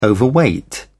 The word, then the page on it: overweight: British English